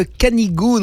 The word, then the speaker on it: Canigou: Non, ça c'est quand on parle pas le français et qu'on sait pas prononcer le ou et le u, hein, c'est ça. C'est bien le Canigou, notre Canigou à nous, entre le Conflans et le Val-Espire.